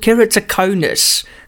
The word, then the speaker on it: keratoconus: It's called keratoconus. How's it? – Hey, you pronounced that perfectly. Well done. I don't hear anything vegetable about that pronunciation, so good one.